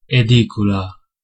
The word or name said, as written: room